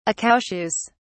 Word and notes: accoucheuse: British English

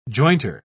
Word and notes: jointer: British English

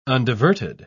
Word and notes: undiverted: British English

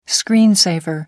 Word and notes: screen saver: American English

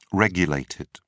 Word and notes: regulated: British English

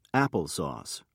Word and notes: apple sauce: British English

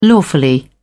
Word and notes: lawfully: British English

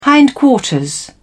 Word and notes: hindquarters: British English

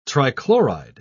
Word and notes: trichloride: British English

